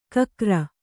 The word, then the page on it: ಘಕಾರ: ghakāra